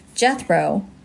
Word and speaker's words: Jethro: Jethro, rhymes with death row,